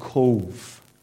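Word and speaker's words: Cobh: And one afternoon, we were given the afternoon off, and Colin said, I'll take you to a place called Cove. It's not spelled C-O-V-E, it's the Irish spelling.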